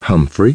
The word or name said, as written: HUMPHREY